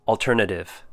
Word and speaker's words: alternative: First word, alternative. Four syllables. The first syllable is all, as in A-L-L, all. Ter, na, short U sound, and tif, like the word if, with a T in front, alternative. Next up, agriculture.